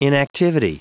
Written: inactivity

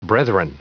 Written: brethren

